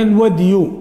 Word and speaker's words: الودي: ثم تحول إلى المسألة الثانية من غير المذي وهي الودي، وقلنا يجوز نطق بالودي والودي، وأما الودي بدال مهملة ابن العربي، ومن رواه بدال معجمة فقد صحف.